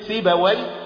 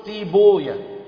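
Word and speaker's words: سيبويه: عارفين كلمة سيباوي؟ أهل الحديث ما يقولوش سيباوي، ما يقولوش وي، يقولوا ويه ده اسم شيطان فما نقولش وي، فما يقولوش سيباوي، وما ينطقوا ايه؟ يقولوا سيبويا. فبرضو ما يقولوش حمدوي، وما يقولوا ايه؟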